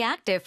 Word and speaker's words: active: Still very active for his age. – Still very active for his age. 우리가 active라는 말을 하는데, active하다. 아주 액티브한 삶을 산다. 근데 active 뒤에 부까지 발음하지 않습니다.